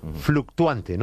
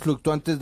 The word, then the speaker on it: fluctuant: Ah, sí, que es las posiciones fluctuantes de Donald Trump respecto a Irán. – Eso es la primera titular. Página. ¿Cómo se dice fluctuante en francés? – Fluctuant.